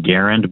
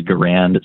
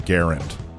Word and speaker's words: Garand: The guy himself pronounced his last name Garand, but everyone says Garand, so whatever you want to say.